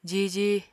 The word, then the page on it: jiji: Apatani